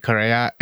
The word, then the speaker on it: Correa: My name is Rithik Correa. It's spelled as R-I-T-H-I-K, last name C-O-R-R-E-A.